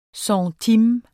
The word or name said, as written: centime